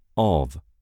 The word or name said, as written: of